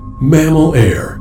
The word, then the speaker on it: Mammal Air: Mammal Air. It rhymes with mammal hair, which is the definitive attribute of mammals.